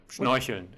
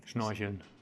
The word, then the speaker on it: Schnorcheln: Schnorcheln. – Spell that. – S-C-H-N-O-R-C-H-E-L-N. Schnorcheln. Schnorcheln. Schnorcheln is a snorkel. – It sounds like you're adding an I, like Schnorchel. – Schnorchel.